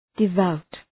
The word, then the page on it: devout: dı’vaʋt